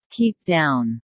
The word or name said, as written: keep down